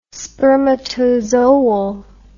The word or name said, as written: spermatozoal